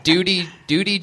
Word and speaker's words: duty: It is pronounced with a liquid U. Duty.